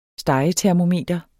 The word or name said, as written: stegetermometer